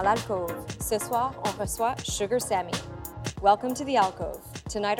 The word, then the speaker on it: Sugar Sammy: Sugar Sammy.